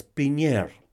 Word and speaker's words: Pigneur: Bueno, esto fue desarrollado por eh, Alexander Osterwalder y Yves Pigner, creo que se pronuncia así, Pigneur, ¿ya?